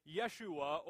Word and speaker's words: Yeshua: The name Jesus is a Greek name, really, that is taken from a Hebrew name, Yeshua, or we would pronounce it Joshua.